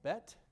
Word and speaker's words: bet: Now, here's the letter B, which is pronounced bet, and that looks a little bit like a tiny house or a teepee or a tent.